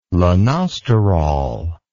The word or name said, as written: lanosterol